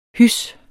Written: HYSSE

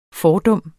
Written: fordum

